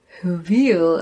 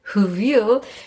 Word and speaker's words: hwyl: Das äh, eine heißt ein Huvil. Also man kann es gar nicht aussprechen. Also H, W, Y, L, Huvil. Und das scheint irgendwie walisisch zu sein.